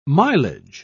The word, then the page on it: milage: British English